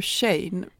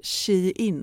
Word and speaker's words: Shein: Vi, vi har pratat om, men nu, nu, nu måste jag ta upp en annan sak säger du Shane? Vi har nämligen fått lära oss att, man, att man ska uttala det Shein.